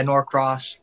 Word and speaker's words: Norcross: Yeah, Norcross.